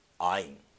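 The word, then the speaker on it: ア イン (עין): アイン。日本語的に言うとアインですね。アイン。日本人が一番発音しにくいアルファベットです。うがいをするように発音しなさいとかいろんなこと言われるんですね。うん、あーって言わないですね。奥から出す音ですね。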